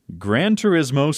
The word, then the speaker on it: Gran Turismo: NBA 2K23, Gran Turismo 7.